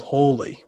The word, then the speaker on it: Hawley: It's fictional, but it's called Hawley, which, as we know, rhymes with Chorley, which isn't too far from us at all.